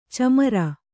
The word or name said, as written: ಛೀಮಾರಿ